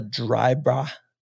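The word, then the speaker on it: Dryburgh: Gemma Drybra. Did I say that right? Drybra.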